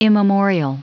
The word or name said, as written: immemorial